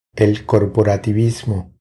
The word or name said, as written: El corporativismo